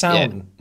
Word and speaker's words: Samhain: If you've ever seen the name like Samhain, and people are like, Samhain, that's pronounced Samhain.